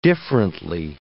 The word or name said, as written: differently